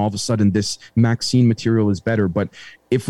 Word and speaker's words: MXene: They said it's pronounced Maxine, like the name, Maxine. Like the female name Maxine.